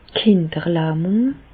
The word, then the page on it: d' Kinderlähmùng: Bas Rhin; Reichshoffen